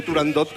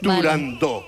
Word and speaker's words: Turandot: pues yo también lo digo mal y digo turandot como todo el mundo. Pero para que quede claro... – Entonces, todos tontos ya. – Para que quede claro, es turandot.